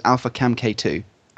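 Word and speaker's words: alpha-CaMKII: The way to pronounce it is alpha-CamK2.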